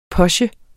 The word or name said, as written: POCHEN